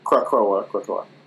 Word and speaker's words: Krakoa: I'm pronouncing that name wrong. – Krakoa.